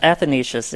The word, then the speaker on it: Athanasius: We just come to you today with you in our Easter presence in this Easter season, and with Saint Athanasius, if I said that right, I hope. Is it Athanasius? – Yes, Athanasius. There you go.